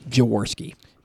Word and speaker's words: Jaworski: Jaworski. – Did I say it wrong? That's all right. – You said Jaroszky.